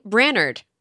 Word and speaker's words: Brainerd: This is the Material Podcast, episode number 218, or 218, as some would say, is the area code in northern Minnesota, including Brainerd, which I'm assuming is how you pronounce that word. – Yes.